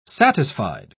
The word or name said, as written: satisfied